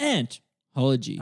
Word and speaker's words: Anthology: Dryden's theory behind Anthology, or Anthology, how are we pronouncing it? – Anthology. – All right, I might just call it Anthology because I don't think my vocal cords can take shouting "ant" all the time.